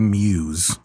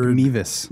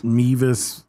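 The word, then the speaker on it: Meiwes: So that, on the documentary, so his name is Armin, it's spelled M-E-I-W-E-S. I would say Muse. – Right. – If I saw that. – But it's not. – But it's some weird. They say like Mevis. – Mevis.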